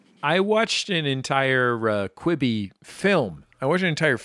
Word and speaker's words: Quibi: Now, I noticed you feel like you pronounce it Queeby. I'm a Quibby guy. – Well, let's call the whole thing off. No, I think I am. I think I am mispronouncing it.